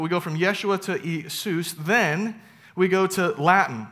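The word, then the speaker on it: Isus (Ἰησοῦς): And so the challenge though becomes there is no yah sound in Greek. And so it can't be Yeshua. They changed the pronunciation of the name Jesus in Greek to Jesus, or Isus, excuse me, Isus. And there's no yah, there's E, Isus.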